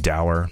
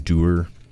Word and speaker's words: Dower: Moving downtown, we have over at the Pearl, you got Dower, that I'm pronouncing Doer this whole time up until about like last month.